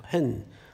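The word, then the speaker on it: 헨 (ἕν): 여러분 이 하나됨이라는 말을 이제 헬라말에 보면 헨이라는 단어를 써요. 한 글자예요. 헨. 물론 스펠은 한 글자가 아니지만 이제 발음은 한 글자예요.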